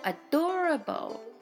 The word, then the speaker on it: adorable: You are so adorable Adorable Adorable 発音とリズムとイントネーションとっても大事になってきます。泥棒って聞こえますが、あという言葉が先に入っています。 Adorable ですね。